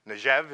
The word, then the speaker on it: Negev: When you go down to the south, I think the Bible calls it, I always mess it up, the Negev. Negev is actually the way it's pronounced. N-G-E-B, the southern part where David hung out with the boys and like Ziklag and everything, that was more deserty.